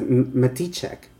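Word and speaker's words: Matichak: I heard Judy Greer pronounce it as Maticek.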